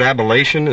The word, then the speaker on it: ablation: Is that fancy word ablation? Is that how you pronounce that? – That's right.